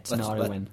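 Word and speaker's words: Tinariwen: Yeah, Tenariwen. – Let's spell it. T-I-N-A-R-I, Tenariwen, W-E-N. – Correct, yes.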